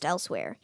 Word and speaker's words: Elsweyr: Khajiit are the native cat people of Elsweyr. It's spelled Elswyre. It's spelled weird, like wire, Elsweyr, but it's spelled it's pronounced Elsweyr.